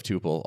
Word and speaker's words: Tuple: So am I pronouncing that right, by the way? Tuple or tuple? – It's actually tuple. – Ah, tuple. My bad. And yeah. Everyone was listening. – I mean, that's not fair. We pronounce it tuple. Tuple is a totally legitimate pronunciation of that word as well.